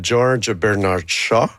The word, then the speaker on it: George Bernard Shaw: Ben, l'inspiration vient de la pièce de George Bernard Shaw, que j'ai prononcée comme à merveille.